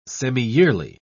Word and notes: semiyearly: British English

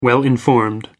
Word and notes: well-informed: American English

